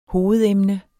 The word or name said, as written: hovedemne